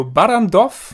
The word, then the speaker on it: Barrandov: Besser gesagt Schauspielern aus der DDR, weil es ist eine Koproduktion der, jetzt kommt wieder die Aussprache, Filmstudio Barrandov.